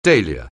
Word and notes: dahlia: anglais

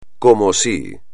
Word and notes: como si: espagnol

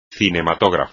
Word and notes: cinematógrafo: espagnol

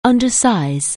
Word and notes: undersize: anglais

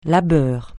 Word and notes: labeur: francais

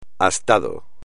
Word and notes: astado: espagnol

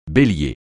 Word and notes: Bélier: francais

